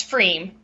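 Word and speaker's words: pFriem: And let's see, the brewery is, oh gosh, how do you pronounce that? Pifrium? – It's Freem. Yeah.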